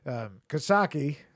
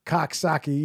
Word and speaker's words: Coxsackie: There's Coxsackie, that's how you say it locally. Coxsackie, you'll hear it on Coxsackie? – I know about it. You'll hear about it on Law and Order pronounced incorrectly.